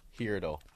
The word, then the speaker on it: Heardle: And it's spelled H-E-A-R-D-L-E, right? Not like a jump. – Like here, Heardle.